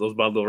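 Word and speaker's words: Oswaldo: My name's spelled with a W, but it's pronounced with a B. And the famous goalkeeper was known as Osvaldo Sanchez, but I go as Osvaldo Reyes.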